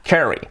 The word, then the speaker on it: Carey: Y las palabras que acaban en EI, pues como Disney, que decimos que realmente se dice Disney, o Sydney, la ciudad que es Sydney, pues Carey es Carey. So, Mariah Carey.